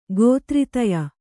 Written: ಗೋತ್ರಿತಯ

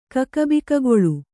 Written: ಕಕಬಿಕಗೊಳು

